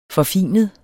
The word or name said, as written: forfinet